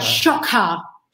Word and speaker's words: XOC: It's pronounced Shaka, right? – Shaka. – Shaka. Okay. – Kind of like shock and awe, but you put a ha on the end.